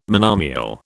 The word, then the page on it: monomial: British English